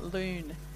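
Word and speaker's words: Lune: I have to say probably the best croissant I've tried is at Loon, bakery in Fitzroy.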